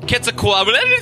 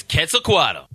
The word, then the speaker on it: Quetzalcoatl: And in native Nahuatl, the pronunciation is quite different. It's pronounced Quetzalcoatl. But that's really hard to pronounce, so the accepted English pronunciation is Quetzalcoatl.